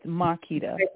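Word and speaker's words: Makeda: It's M-A-K-E-D-A. – So Yeah, it's Marquita, but I just cut it down short to just Kita.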